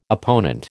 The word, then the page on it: opponent: American English